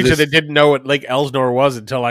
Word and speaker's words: Elsinore: Did I keep saying it wrong? Is it Lake Elsinore? – Lake Elsinore, yeah. – I keep saying Lake Elsinore. – Yeah. It's Elsinore. And that might be a regional thing.